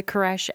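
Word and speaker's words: crèche: It's spelled C-R-E-C-H-E. – Okay. – Creche. And the E has a circumflex over it if you know French.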